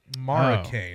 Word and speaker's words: Marocain: M-A-R. A second R. E-C-A-N-E. I don't know. – It was M-A-R-O-C-A-I-N, Maracane.